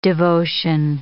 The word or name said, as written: devotion